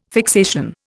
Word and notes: fixation: Indian

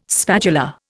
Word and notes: spatula: Indian

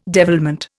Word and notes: devilment: Indian

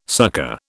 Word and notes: succor: British English

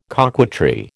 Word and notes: coquetry: American English

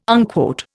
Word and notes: unquote: Indian

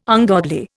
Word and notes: ungodly: Indian

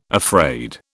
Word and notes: afraid: British English

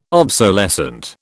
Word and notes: obsolescent: British English

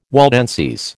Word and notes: Waldenses: American English

